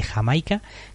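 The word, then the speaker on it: D'yer Mak'er: Este corte sería un indisimulado homenaje a ese sonido, la canción escrita Dire Maker, pero pronunciada Jamaica.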